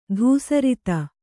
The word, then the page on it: ಧೂಸರಿತ: dhūsarita